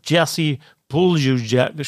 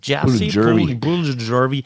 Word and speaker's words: Jesse Puljujärvi: Jesse Puljujärvi, ich helfe nochmal mit der Aussprache, Jesse Puljujärvi, der war jetzt für die Florida Panthers in dieser Saison nicht so wichtig, aber ist es eben für die Charlotte Checkers.